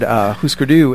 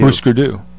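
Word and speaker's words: Hüsker Dü: Thank you for pronouncing that correctly. I don't know if that might be. – Oh, Husker Du? – Yeah, Husker Du. Yeah. – I think they were on a list of most commonly mispronounced names. – Which is weird because the two dots are under both you or above both yous. – Yeah, so it's Husker Du.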